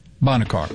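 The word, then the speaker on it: Bonnet Carré: And so they open up the Boncarry something? Is that the right pronunciation? – Bonnet Car.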